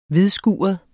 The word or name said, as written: hvidskuret